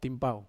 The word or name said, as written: pau